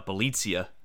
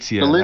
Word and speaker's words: Bjelica: I think it's uh, Bjelica. Bjelica, that's right.